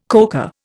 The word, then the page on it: coca: Indian